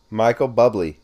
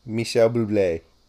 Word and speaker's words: Bublé: Michael Bubbly, Canadian singer, crooner, big band singer, and actor. Name. Say it right. Say it right. – Michel Bublé.